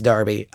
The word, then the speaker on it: Derby: Spelled Derby, pronounced Derby.